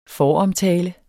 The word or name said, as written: foromtale